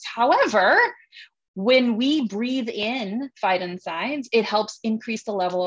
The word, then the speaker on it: phytoncides: And I found out that there is this chemical, this airborne chemical that plants produce, phytoncides. And I loved researching this. I loved learning how to say it also, phytoncides. But phyton means plant, inside means exterminate.